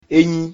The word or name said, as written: Enyi